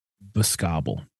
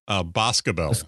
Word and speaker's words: Boscobel: I'm going to go with Boscobble. – That is actually pronounced Boscobel. – Ah, I had it. I had the emphasis on the wrong syllable.